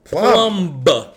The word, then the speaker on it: Plumb: Eve Plumb. Hard B on the end of that. – Actually, I believe the M is silent. – Okay, no, so club. Sat next to Eve. – The club. – P is silent.